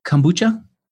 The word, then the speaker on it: kombucha: Am I pronouncing it right? Kombucha? – Kombucha. – Okay, good. I guess sometimes I say kombucha and she laughs.